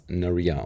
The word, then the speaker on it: Naria: Ich persönlich sehe es als, also für mich ist es ein Mädchenname und ich selber spreche es als Naria aus, also mit Betonung auf die zweite Silbe.